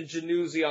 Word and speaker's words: Yanuzi: Yanuzi is a 6'2", 175 listed left-hander from Venezuela, and it's pronounced Jefferson Januzzi on the radio, but it's spelled with a Y.